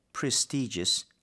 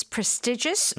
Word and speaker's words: prestigious: 근데 중요한 건 most prestigious law firms. 벌써 느낌이 되게 고급일 것 같아요. – Okay, so you can pronounce this prestigious or prestigious. Both right.